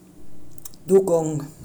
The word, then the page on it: Dugong: German